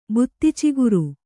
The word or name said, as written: ಬುತ್ತಿಚಿಗುರು